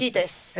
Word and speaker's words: esquites: Esquites. – It. Esquites.